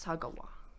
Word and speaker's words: Sagawa: I know how to say his last name. It's Sagawa. Issei.